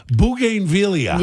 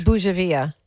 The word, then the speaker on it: bougainvillea: B-O-U-G-A-I-N-V-I-L-L-E-A. You know how I would say that? Bougainvillea. – Yeah, that's completely wrong. – And what did you say it is? – Bougainvillea. – Come on, Bougainvillea.